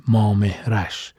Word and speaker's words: مامهرش: ما خیلی سرچ کردیم گشتیم توی گوگل و کتاب ها و جای دیگه که تلفظ صحیحش رو پیدا کنیم با چند تا از دوستان کردمون هم تماس گرفتیم نتونستیم قبل از ضبط پیداشون کنیم اما بالاخره فهمیدیم ما مهرش گویا صحیح ترین تلفظ